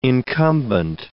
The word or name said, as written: incumbent